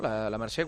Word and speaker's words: La Mercè: ¿La Mercè cuándo es? – Sí, la Mercè es la semana que viene.